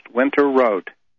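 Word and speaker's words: Winterrowd: And then add another R-O-W-D, two R's. It's pronounced Winter Road.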